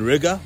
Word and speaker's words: Riga: You guys remember that Latvian chick I met a couple of weeks ago in Riga, which rhymes with nigga?